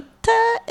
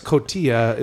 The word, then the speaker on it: Cotija: Cojita is how you thought it was pronounced? – No, Cojita. – Cojita. – Is how it's pronounced. – No, it's Cotija is how you say, is it actually.